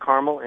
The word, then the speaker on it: Carmel: I like Carmel, but it's called pronounced Carmel.